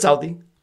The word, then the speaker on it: Southie: Do you call it Southie? – Yeah, it's Southie.